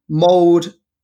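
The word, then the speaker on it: Mold: He's to go from Mould, that's how you pronounce it, to Cardiff and then to Manchester United.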